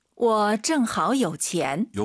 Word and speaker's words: 我正好有钱: 我正好有钱. – 여기서는 정하오 올라가야죠.